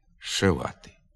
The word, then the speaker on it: зшивати: Наприклад, пишеться «зчепити», «зчесати», «зчистити», «зшивати», а вимовляється «щепити», «щесати», «щистити», «шивати».